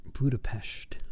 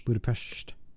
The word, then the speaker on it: Budapest: But it's P-E-S-T. There's no sh in there. – That's how you pronounce it. – But phonetically, Budapest is right. – But in that part of Europe, there's the S-H sound. – Okay. – So, you know. – So S just becomes sh.